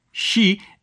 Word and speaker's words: shi: Because in Japanese, the word for four, shi, is pronounced the same as the word for death.